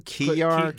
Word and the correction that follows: Kilar: Wojciech Kilar.